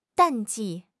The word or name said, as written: 淡季